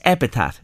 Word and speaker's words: epitaph: What would your apple tart be? Am I saying that right? – Mm-hmm. – Your well, it sounds like it, doesn't it? It's epitaph. Mm. Am I saying epitaph? Epitaph. – Epitaph. – Epitaph. Yeah, so your epitaph on your headstone.